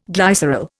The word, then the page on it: glycerol: Indian